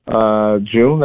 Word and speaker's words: June: June, like June, July.